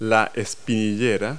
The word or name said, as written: shin pads